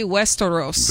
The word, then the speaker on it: Westeros: That would be Westeros.